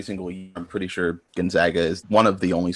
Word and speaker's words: Gonzaga: All right, Captain Insano shows no mercy, checks in with Gonzaga with the proper pronunciation, not Gonzaga. It's Gonzaga, I believe.